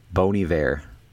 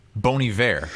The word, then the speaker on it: Bon Iver: Bon Iver. – Bon Iver.